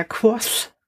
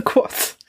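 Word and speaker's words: Kvothe: Und soweit ich mich erinnere, sagte er Quoth. So mit so einem Spucker hinten dran. So Quoth wo immer so ein bisschen was noch mit rauskommt.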